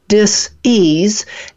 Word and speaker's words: dis-ease: Okay, so when a person has a particular dis-ease, and I say that in two syllables because that's really what the problem is being out of ease.